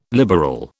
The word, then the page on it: liberal: British English